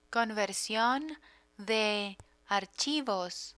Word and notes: conversion de archivos: Spanish

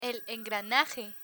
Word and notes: el engranaje: Spanish

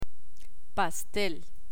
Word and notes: pastel: Spanish

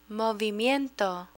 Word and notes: movimiento: Spanish

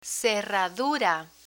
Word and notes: cerradura: spanish

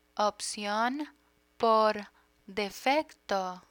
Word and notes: opcion por defecto: spanish

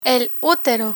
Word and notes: utero: spanish

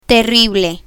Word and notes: terrible: spanish

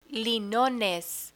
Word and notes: linones: Spanish

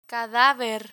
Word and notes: cadaver: spanish

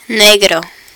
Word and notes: negro: Spanish

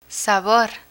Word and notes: sabor: spanish

